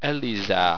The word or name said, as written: elizare